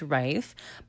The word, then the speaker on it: Reith: Wow, I sound like a news anchor now, but I figured I'd say my last name specifically because a lot of people don't know how to say it, so it's Reith.